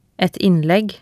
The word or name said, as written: et innlegg